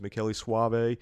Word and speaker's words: Michele Soavi: Who has assured me he has found out that is the correct pronunciation. – It is. I've multiple sources. I've seen Rupert Everett talk about him in an interview, and it was Michele Soavi.